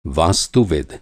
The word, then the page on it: वास्तुविद: Hindi